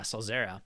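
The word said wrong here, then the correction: Sollozzo: Salazo. Okay. I added a few extra syllables.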